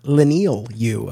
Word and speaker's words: Leinil Yu: As it turns out, it's Leinil Yu, uh, uh, according to, uh, according to, uh, uh to, to Mark Waid.